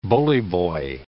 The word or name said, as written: bullyboy